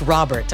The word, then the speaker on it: Rol3ert: You know, interestingly, his name is spelled as R-O-L-3-E-R-T, but it's really just Robert, okay? He's just writing it in a funky way.